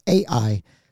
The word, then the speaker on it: Ai: so let's go on to Ai. That's how I always pronounce it. – That's how I say it, too.